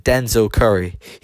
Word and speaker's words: Denzel Curry: I think he's quite underground, kind of like how Dizelle, or was it, how do you say his name again? Dizelle Curry, Denzel, that's it, Denzel Curry.